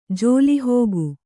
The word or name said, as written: ಜೋಲಿಹೋಗು